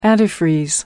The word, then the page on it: antifreeze: American English